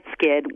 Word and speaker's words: Skydd: It's a Swedish company that created this patented product, and it's spelled Plant Skydd, and it's just really coming more and more into garden centers now.